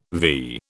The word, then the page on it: vee: British English